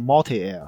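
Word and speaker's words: MultiAir: 对，它应该是读作 Multi Air 啊。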